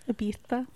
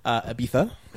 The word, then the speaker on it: Ibiza: I watched Ibiza on Netflix. Starring... Ibiza. What's that? – Ibiza. – Yes. Excuse me. I was not using the correct proper Spanish pronunciation. Ibiza on Netflix.